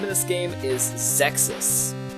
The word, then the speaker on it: Xexyz: I found a commercial for it though, and it said the name of the game out loud. So I finally like learned how to say it out loud like a week ago. – And so The game is spelled X E X Y Z.